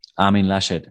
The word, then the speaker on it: Laschet: What about am I pronouncing his name correctly? Armin Laschet? – Armin Laschet. – That was close. That's pretty close. I didn't know if it was Laschet. I was going to go French.